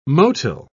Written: motile

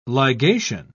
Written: ligation

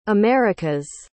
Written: america's

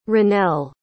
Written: Ranell

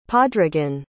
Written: padraigin

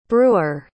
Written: breuer

